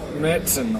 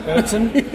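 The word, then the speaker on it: Märzen: I think you pronounce it like Mertzen or something, which okay. is a it's a German style. I'm going to say lager. – Do you know which one's which? – Mertzen.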